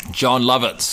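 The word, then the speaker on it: Jon Lovitz: John Lovitz.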